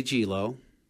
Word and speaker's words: Gelo: Randy Gelo. – Randy Gelo? – Yeah, G-E-L-O.